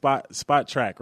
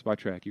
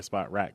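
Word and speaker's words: Spotrac: It's pronounced SpotTrack, right? – SpotTrack. You got it. Yep. – All right. Yeah, we were going back and forth on this because I kept saying, is it SpotTrack? No, you've been saying it right. Jay, and I think I asked you, too, if it was SpotTrack or SpotRack because of the way it's spelled.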